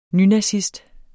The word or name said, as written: nynazist